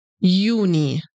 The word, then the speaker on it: Juni: The J at the beginning is pronounced like Y, like in yes. Juni. Juni.